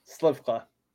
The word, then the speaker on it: Slywka: Mr. Chris Sarwak. Did I say that right? – No. – Oh, God. – It's Chris Slifka. – Slifka. – Yeah, you're good.